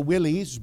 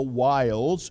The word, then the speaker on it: wiles: Some people pronounce that the willies, but that's not how you would pronounce that. It'd be the wiles of the devil.